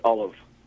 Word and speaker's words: Ollam: You're pronouncing it traditionally in the sense that most people do say Alam, but it's actually pronounced Olive. – Olive with a V? – Almost with a V sound, yes. – But it's spelled it's spelled O-L-L-A-M.